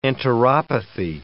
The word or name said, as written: enteropathy